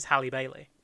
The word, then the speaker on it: Halle Bailey: I want to say, I don't know if it's pronounced Halle Bailey? – It's like, because her first name is the same as Halle Berry. – Yeah. – But her name is Halle Bailey.